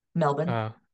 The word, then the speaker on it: Melbourne: Yeah, well, also I just had it stewing in my head and it's nothing important, but how do you say it? Is it Melbourne? – Melbourne. – Melbourne. Are they like the Louisville of Australia, just in making you really say their name in an odd way and kind of swallow syllables and vowels? – I think part of it's the accent.